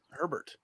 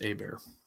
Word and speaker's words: Hebert: I should have known that as a fellow Frenchman, but I thought it was Herbert. – I am like 90% sure it's Hebert. – How's it spelled? Because I thought it was... – It's spelled like Justin Herbert, but without the R.